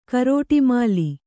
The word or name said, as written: ಕರೋಟಿಮಾಲಿ